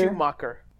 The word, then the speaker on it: Schumacher: RIP, because Joel Schumer just passed away in 2020, so RIP to him. But great director. – Schumacher. – Yes, yes. Is that, did I mispronounce his last name? I'm sorry. Joel Schumacher.